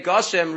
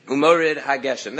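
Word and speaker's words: HaGeshem (גשם): So therefore, there's no reason to say Goshem, really the appropriate nikud, the appropriate pronunciation should be Mashiv Haruach Umorid HaGeshem.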